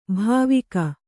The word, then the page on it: ಭಾವುಕ: bhāvuka